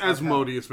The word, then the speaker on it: Asmodeus: It's Asmodeus, man. I know it technically could pronounce it either way, but he's not fucking Mozart.